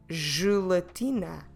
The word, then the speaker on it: gelatina: Gelatina.